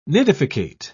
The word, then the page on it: nidificate: British English